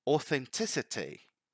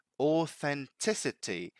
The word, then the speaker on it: authenticity: Authenticity. Authenticity. So before giving you the meaning of it and the examples, mind the pronunciation here, because here we have the T, the voiceless TH. Th authenticity. Authenticity.